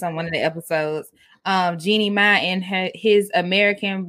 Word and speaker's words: Jeannie Mai: So Jeezy and Jeannie Mai, I can't ever say her name right. – You said it, girl. You said it right.